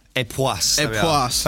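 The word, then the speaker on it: Époisses: So could we just could you pronounce that? – Pronounce. – A poisse. – Epoisse. Thanks, HOH.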